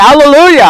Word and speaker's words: alleluia: This is a Greek word, and when we say alleluia, we're actually pronouncing the Greek word. It's transliterated, not translated. Transliterated. So we're actually pronouncing the very Greek word that is in the text of this scripture.